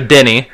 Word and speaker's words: Deni: You're right, so Denny. Yeah, D-E-N-I, Denny. And then Avdia.